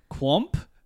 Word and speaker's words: Qomp: I think it's probably pronounced Quomp, but it's spelled Q-O-M-P.